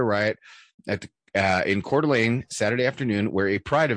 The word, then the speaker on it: Coeur d'Alene: Anyway, no, so it's pronounced Coeur d'Alene, and it was in Kootenai, Kootenai County. Little, that's how it's pronounced. I thought it was Yee-hoo.